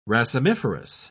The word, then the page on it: racemiferous: British English